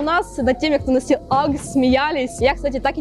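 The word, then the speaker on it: UGG: Да это же уги, скажете вы. А вот и нет. Сейчас вы точно так же удивитесь, как и я, когда впервые услышала, как правильно произносить название этого бренда. Акс, Акс, ребята, представляете?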